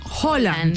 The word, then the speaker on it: Haaland: Porque aquí lo dijimos desde hace varios días, que era Haaland.